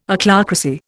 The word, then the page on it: Ochlocracy: Indian